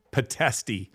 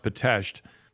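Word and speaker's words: Pitești: It looks like Pitesti, but it's pronounced Pitesti.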